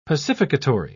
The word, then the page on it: pacificatory: British English